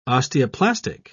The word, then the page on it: osteoplastic: British English